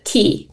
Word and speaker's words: CII: We pronounce it key.